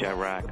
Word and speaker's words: Chiraq: How do you say it? Chirac.